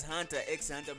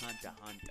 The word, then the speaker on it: Hunter x Hunter: Yes, it appears Hunter x Hunter, but it's not pronounced like that. It's pronounced Hunter Hunter. – So what? X is silent.